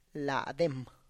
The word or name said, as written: ladem